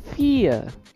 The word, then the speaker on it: fear: Ketakutan itu cara membacanya adalah fear. Fear. Gitu. Fear. Mau dia bakal coba baca fear, fear, fear. Pokoknya harus fear. Dan itu wajib. Fear. Kalau bisa baca fair, itu nanti artinya yang seperti yang tadi.